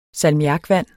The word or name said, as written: salmiakvand